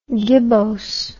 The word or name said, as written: gibbose